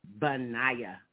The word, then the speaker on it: Benaiah: Benaiah. B like in baby, ba. The E is really silent. I'm going to spell it B-E-N-A-I-A-H. Benaiah. And then the E again is silent. B and Naya. N-A-I-A-H.